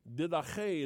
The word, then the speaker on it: Didache: And around the beginning of the first century in the, and I think in Greek this is, I don't know how you say it in English. Didache is how you say it in Greek.